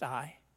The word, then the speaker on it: Ai: The city of Ai, pronounced I.